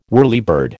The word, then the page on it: whirlybird: American English